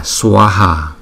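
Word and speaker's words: svāhā: swaha. Chữ s và chữ v đi chung với nhau thì chúng ta đọc là sawa swa swaha swaha. Bây giờ chúng ta sẽ qua phần ý nghĩa của từ ngữ.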